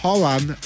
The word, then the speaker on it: Haaland: Erling Haaland, o Haaland, así se dice, Haaland, así se pronuncia, dice que, de Noruega, ya con 7 goles, ya no tiene ninguna posibilidad.